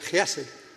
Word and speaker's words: hesed: It's pronounced hesed, H-E-S-E-D, with a bit of a guttural on the H, not hesed, but chesed.